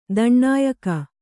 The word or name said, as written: ದಣ್ಣಾಯ್ಕ